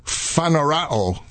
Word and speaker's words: phaneroo: Again, with pronunciation, paneru or paneru. P-H-A-N-E-R-O-O. – Phanerao. – Phanerao. Oh, see, now you know.